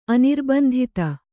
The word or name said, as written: ಅನಿರ್ಬಂಧಿತ